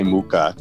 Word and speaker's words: Mucha: Alphonse, you know, and his last name is pronounced a million different ways depending on where you're from. I'm going to say Mucha.